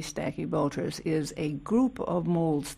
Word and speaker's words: Stachybotrys: Stachybotrys.